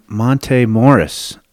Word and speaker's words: Monte Morris: Speaking of reliable, consistent point guards, Mike Singer, you did a story on Monte Morris uh, this last Sunday.